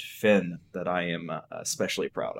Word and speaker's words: Fenn: Fenn is how I normally say it.